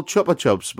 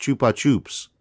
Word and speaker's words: Chupa Chups: Yeah, but Zool had an amazing kind of sponsorship deal with what I call Chupa Chups, but I've been reliably informed is pronounced Chupa Chups. – Whoever said that, right, take him one side. – No. Do you know how it was explained to me? They said, it's not Chupa Chups, it's Chupa Chups, because it's...